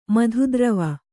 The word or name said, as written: ಮಧುದ್ರವ